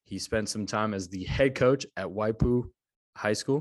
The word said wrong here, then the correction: Waipahu: Did I say that right, Coach? Waipahu, yeah. Waipahu. Close enough.